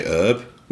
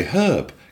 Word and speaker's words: herb: It's like, you know, we say, you say herb, we say herb, because it's spelt with a fucking H.